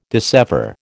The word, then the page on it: dissever: American English